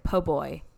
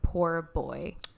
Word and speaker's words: po'boy: White racists, you know, kind of mocked, used in comedy, the way that African Americans often pronounced it, po'boy, instead of poor boy.